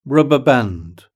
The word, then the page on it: rubber band: British English